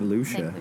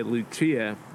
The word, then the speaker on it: Lucia: Saint Lucia. – Lucia. I used to say Lucia before I just found out that I was saying it wrong.